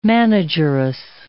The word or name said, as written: manageress